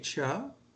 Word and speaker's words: Choi: Wouldn't that be KJ Chuh? – Yeah, well, I guess it's been pounded in my brain for 20 years that over here, that's how they pronounce his name.